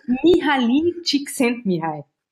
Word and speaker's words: Mihaly Csikszentmihalyi: Mihaly Csikszentmihalyi.